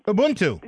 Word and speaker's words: Ubuntu: And though I may not say this right, Ubuntu? – Ubuntu.